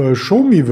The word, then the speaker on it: Xiaomi: Auf Xiaomi wird es ausgesprochen, geschrieben wird es Xiaomi.